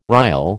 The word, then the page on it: rille: American English